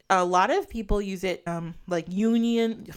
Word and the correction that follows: Jungian: I can never say his name. Use it Jungian. – Jungian.